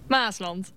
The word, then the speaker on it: Maasland: Maasland. – Dus de klemtoon op Maas.